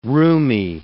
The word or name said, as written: rheumy